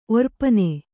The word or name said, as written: ಒರ್ಪನೆ